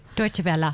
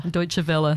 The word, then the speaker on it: Deutsche Welle: Vela, I think that's the right way to say it, isn't it? – Deutsche Welle. – Deutsche Welle, thank you.